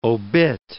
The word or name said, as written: obit